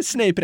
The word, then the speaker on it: Snape: Den här appen fungerar sämre än mitt förra äktenskap och får mig att må sämre än när Snape dödade Dumbledore. 10 av 10 sadister rekommenderar. – Ja. – Är Snape rätt uttal eller? – Ja, det är helt rätt.